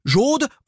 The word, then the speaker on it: Jaude: Ah oui, attention, hein, ça s'écrit J-A-U-D-E, mais on est au sud de la Loire, hein, on est chez les Occitans. C'est la langue d'oc ici, donc on dit pas Jaude, on dit Jaude, un peu comme euh, jaune, vous voyez ce que je veux dire C'est le sud, quoi.